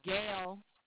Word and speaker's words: Gail: Last name is Stitgen, which is S-T-I-T-G-E-N. And then the first name is Gail, G-A-I-L.